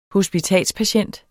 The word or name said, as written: hospitalspatient